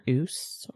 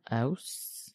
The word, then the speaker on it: Ouse: Their city walls have a walkway on both sides of the River Ouse or Ouse. – How do you spell that? – O-U-S-E.